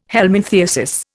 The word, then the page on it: helminthiasis: Indian